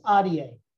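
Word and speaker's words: Ahdieh: His name is Bobby, and his last name is Adier. It's like adios, but with an A at the end instead of an os."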